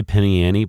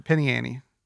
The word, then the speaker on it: penny ante: You know, I've heard people say Penny Annie, but they don't pronounce the T in ante very hard. – Yeah, Penny Annie.